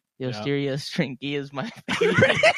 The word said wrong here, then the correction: Osteo Striga: Please learn how to say it right. – Pronounce it right. Osteo String Cheese? – Yeah, Osteo Striga. Yeah. – That's right.